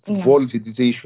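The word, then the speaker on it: Wolfdietrich: اسمه فولف ديتريش، فولف ديتريش، ويعني يختصرون اسمه ب W، و W تنطق في الألمانية V، V هو V إذا تكتب W بالإنجليزية.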